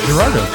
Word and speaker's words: Gerardo: Kind of like Gerardo, Rico Suave. – That's a hard G. – Hard G. – It's supposed to be a soft G.